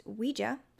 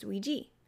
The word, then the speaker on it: Ouija: I also found a source that says in Europe it's pronounced Ouija, and in the US it's pronounced Ouija. So for the sake of not having an exact answer on this, I may say it both ways throughout, and I may not.